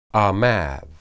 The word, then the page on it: amad: Hebrew